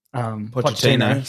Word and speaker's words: Pochettino: Pochettino.